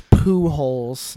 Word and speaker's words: Pujols: Yes, his name is Pujols. Not spelled like you would hope, but Pujols.